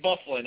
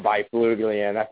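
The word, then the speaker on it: Byfuglien: How do you get Bufflin out of that massacre of letters put together? How does that happen? – I like By Fuglian.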